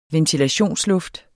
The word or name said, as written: ventilationsluft